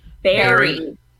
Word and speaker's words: buried: And after being buried alive, do I say buried? Buried weird? How do you say that word? It's spelled buried, but it's pronounced berry.